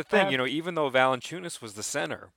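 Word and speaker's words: Valančiūnas: they started Jonas Valanciunas.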